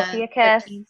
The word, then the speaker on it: Ophiuchus: Oh, like, is it Ophiuchus? Is that how you say it? – Yeah, Ophiuchus.